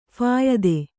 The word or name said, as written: ಫಾಯಿದೆ